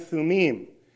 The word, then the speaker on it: Thummim: or sometimes people pronounce it Urim and Thummim. Okay, but in Hebrew, the I-M is plural, and it's pronounced im. So Urim and Thummim, two stones, and they would actually light up if you...